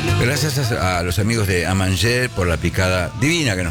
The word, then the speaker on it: Amanger: Los podés seguir en Amanger, así Amanger, vamos a pronunciarlo radialmente, -Palermo.